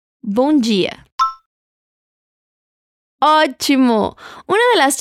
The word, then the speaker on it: bom: Atención a la pronunciación de bom. Es como si le agregaras una U a la palabra después de la O y terminaras con la N.